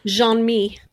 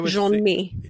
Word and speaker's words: Jean-Mi: Jean-Mi. – Is it Jean-Mi? I thought it was Jean-Mi. Oh, okay. Jean, like J-E-A-N, the French pronunciation, and then Mi, M-I. Jean-Mi.